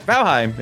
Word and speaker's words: Valheim: Valheim was just to be, you know, annoying. But Valheim is actually how you say it.